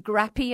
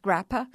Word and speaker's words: grappa: And he said, look, I can sell Appanoose liquor, which was called, it looks like grappy as it's written down, but local people have told me it's pronounced grappa.